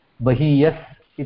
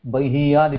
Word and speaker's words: बहीयान्: बकारोत्तर यः अकारः, तस्य अनन्तरं इत्युक्ते बहीय, बहीयान् इत्येव वक्तव्यं, न तु बहीय.